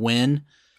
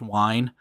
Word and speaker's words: Wein: So I think I've said his name on this show before, but I think I said Len Wein. – Okay. – And I think more of my research has discovered it's pronounced Wine.